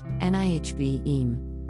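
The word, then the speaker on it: Nevi'im: a Hebrew noun pronounced nihvem, the second of the three books that comprise the Tanakh, along with Torah and Ketuvim.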